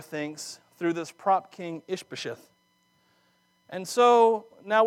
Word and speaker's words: Ishbosheth: Oh, I said it right, I didn't even look at it. Ishbosheth.